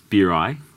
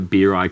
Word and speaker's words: Bury: Well, mine, the way that, it's Bury, how you pronounce it, but way that Bury came about. – Yeah, we're debating that before you came in. – It's like beer in your eye. That's a good way to remember it.